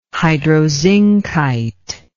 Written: hydrozincite

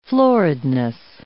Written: floridness

